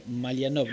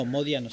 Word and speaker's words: Modiano: Te había entendido Malianov. No, Modiano, sí.